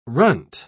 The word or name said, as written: runt